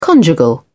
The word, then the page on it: conjugal: British English